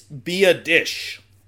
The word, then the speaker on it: Biadasz: First off, it's Biadasz. It's Biadasz. – Yeah, okay. which is totally not what you would think based Biadasz. On how it's spelled.